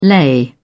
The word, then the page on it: lei: British English